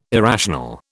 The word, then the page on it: irrational: British English